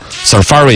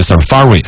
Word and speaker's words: Safari: 他还专门搜了一个这个英语字典那个出来实际的发音的 Safari 应该是这样子读，嘎。 Safari 应该是重音在第二个，好像我之前是有印象的 Safari 浏览器，苹果的浏览器 Safari。英式和美式的发音呢，稍微长一点，短一点，英式的要长一点，Safari 啊，Safari，听见差不多了，我要拽一点回来，表示我也懂啊，Safari 啊，浏览器在肯尼亚去耍，就是肯尼亚当地一种土著的语言里头有 Safari 这个单词，就是探索、去打猎啊什么之类的。